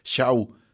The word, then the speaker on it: Shaw: Estamos en este momento platicando de Marlena Shaw, o si quieren decirlo de otra manera, Shaw, así se escribe, S-H-A-W, Marlena Shaw.